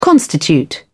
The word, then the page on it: constitute: British English